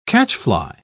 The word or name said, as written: catchfly